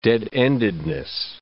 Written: dead-endedness

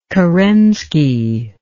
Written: kerenski

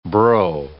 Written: bro

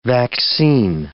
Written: vaccine

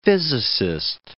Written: physicist

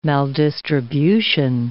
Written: maldistribution

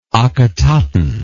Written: Akhetaton

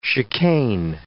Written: chicane

